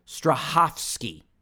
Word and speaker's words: Strahovski: I'm going to slowly pronounce this name. Strahovski. – Yeah, you got it right.